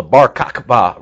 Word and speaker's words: Bar Kokhba: It's called the Bar Kokhba Revolt.